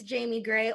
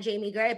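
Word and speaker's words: Jaimi Gray: You can find me on Twitter at It's Jamie Gray, or better yet, on Instagram at Jamie Gray, but you got to spell it right.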